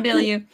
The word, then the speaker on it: Bilyeu: Is it Tom Bilyeu or something like that? Bailey? – You said it perfectly. Tom Bilyeu.